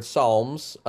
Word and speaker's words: Psalms: Like the reason I called it songs in a lot of ways with the silent P was partly to suggest, you know, it's from the word Psalms, but it's not suggest anything religious.